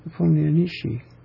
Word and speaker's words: Nepomniachtchi: How do you pronounce it? Nepomniachtchi.